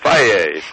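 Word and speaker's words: Fage: How you pronounce that? I've used it for years. – Everything in Greek is Opa, Faye.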